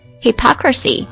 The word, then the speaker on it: hypocrisy: Their promises turned out to be full of sham and hypocrisy. 注意一下 ,hypocrisy. H-Y-P-O-C-R-I-S-Y 可能平时这个单词读音呢可能会弄错，它的读音重音在第二个音节上。